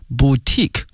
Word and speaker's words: boutique: Galleries 啊，画廊啊，galleries， museums 博物馆，and boutique hotels， boutique 看这个词，精品哈，b o u t i q u e 哈，它的发音是 boutique， boutique hotels， and a nice restaurant，后面出现了与居民区和谐相处，harmony，哎，和谐，harmony，h a r m o n y，harmony，前面加介词 in。